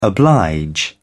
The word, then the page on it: oblige: British English